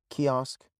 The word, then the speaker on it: kiosk: It's pronounced kiosk. So ki. Kiosk. Kiosk. Kiosk. Key, like a key for a car. Osk, like Oscar, the name. Kiosker. Kiosk. And you stop right there at the sound.